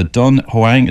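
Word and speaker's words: Dunhuang: It's a famous document, actually, a star chart called the Dun Huang. Is that how you pronounce it? Star chart? – Dun Huang, yes.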